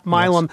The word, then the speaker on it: Milam: We should say the people who were responsible for this murder, Roy Bryant, that was Carolyn Bryant's husband, and his half-brother, J.W., is it Milam? Am I pronouncing that Milam? – Yeah, Milam. – Yes.